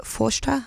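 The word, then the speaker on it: Páiste: That song's called Forschte. It's spelled P-H-A-I-S-T-E if you want to go and look it up and listen to it again, which means child in Irish.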